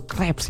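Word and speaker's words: crêpes: yang mirip kayak krepes, dimana pakai sup lentil sama rempah teman pro 2, bukan krepes sih bacanya yang benar ya, itu crepes ya.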